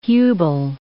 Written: Hubel

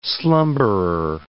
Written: slumberer

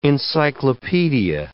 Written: encyclopedia

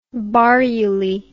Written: barruly

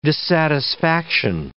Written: dissatisfaction

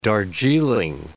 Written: Darjeeling

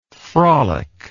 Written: frolicker

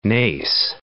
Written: Naas

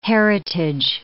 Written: heritage

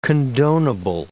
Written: condonable

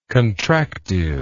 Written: contractiveness

